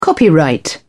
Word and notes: copyright: British English

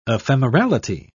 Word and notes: ephemerality: British English

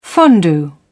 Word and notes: fondue: British English

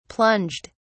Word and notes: plunged: British English

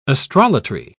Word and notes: astrolatry: British English

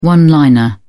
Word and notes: one liner: British English

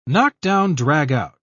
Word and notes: knockdown-dragout: British English

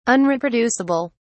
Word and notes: unreproducible: British English

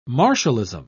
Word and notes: martialism: British English

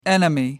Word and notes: enemy: American English

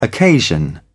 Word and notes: occasion: British English

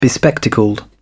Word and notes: bespectacled: British English

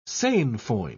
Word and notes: sainfoin: British English

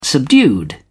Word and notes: subdued: British English

